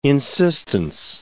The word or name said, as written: insistence